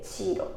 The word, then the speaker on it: CHEERO: チーロのねスペルは CHEERO、 CHEERO でチーロって読みます。